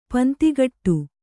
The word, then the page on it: ಪಿಂದುಗಡೆ: pindugaḍe